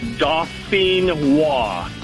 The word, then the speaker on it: Dauphinois: All right, so pronounce that for me one more time so I don't sound like a complete idiot when I order them. – No problem. It's, like I said, it's French. It's Dauphinois.